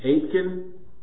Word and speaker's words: Aitken: So, it was Robert Aitken, how do we say this? – Aitken. – It was Robert Aitken of Scotland,